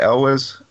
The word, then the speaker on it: Elwes: Elwes? – Right.